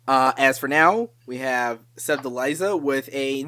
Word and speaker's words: Sevdaliza: This one is pronounced Sevdaliza, coming in with track number 23.